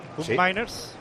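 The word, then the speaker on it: Koopmeiners: Es así como se dice Kuhn Miners. – ¿Sí? Está aquí León, ¿cómo se dice? – ¿A León? – Sí, es Miners, sí, correcto.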